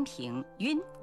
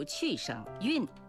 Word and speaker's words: 晕: 大家还记得吧，我们在节目里说过，晕是多音字，在晕倒、晕厥、晕头转向等词汇中读音平晕，而在眩晕、晕车、晕船等词汇中读去声晕。